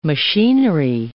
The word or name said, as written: machinery